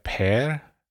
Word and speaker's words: Per: Per, per was the first? Per. Yeah, that's like Pierre in French, but it's Per, like Peter, actually.